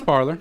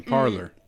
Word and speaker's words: Parler: Yeah, it's Parler. It's like parlay. Parler.